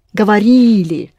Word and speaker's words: говорили: Говорили. Ри is always accented.